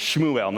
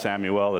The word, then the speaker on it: Shmuel: If you listen to Bema, it's Shmuel, not Samuel.